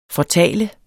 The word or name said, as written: fortaler